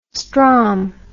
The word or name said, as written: stromb